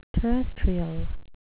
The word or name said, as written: terrestrial